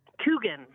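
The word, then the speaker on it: Kuchen: Kookin or Kookin? – Kookin. – Kookin. – I guess that's how I pronounce it.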